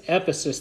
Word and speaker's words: Ephesus: Ephesus, thank you.